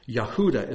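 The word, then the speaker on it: Yehuda (יהודה): Judah, Yehuda is how it's pronounced in Hebrew.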